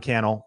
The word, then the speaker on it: Cannell: And with that, my name is Sean Cannell, Rhymes with YouTube channel.